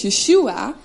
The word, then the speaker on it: Yeshua: Eigenlijk spreek je het uit als Yeshua.